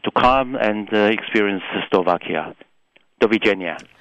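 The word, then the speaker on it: Dovidenia: Goodbye, meanwhile, is Dovigenia.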